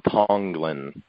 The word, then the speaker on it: Tonglen: You know, Tonglen meditation. Am I saying that right? – Yes, it's okay. Ancient Buddhist a meditation called Tonglen.